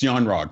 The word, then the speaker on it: Yon Rogg: Yon Ru, that's how you say his name. But anyways, Jude Law's character, he was going to end up on Sakaar when Captain Marvel sent him off. It's Yon Rogg, thank you.